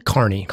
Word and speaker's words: Kearny: Am I pronouncing that correctly? – Kearny. Fort Phil Kearny.